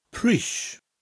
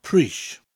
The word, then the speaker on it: pris: Pris. Spelt P-R-I-S. But in some parts of Wales, you'll hear it pronounced pris rather than Prish.